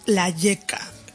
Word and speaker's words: La Lleca: Sí, nosotras y nosotros nos llamamos La Yeca, que se escribe LA espacio LLSA, y que significa la calle.